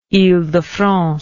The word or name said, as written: ile de france